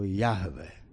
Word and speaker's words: Jahve: Meno, ktoré môžeme čítať ako Jahve, ale v zásade je to tetragram JHVH.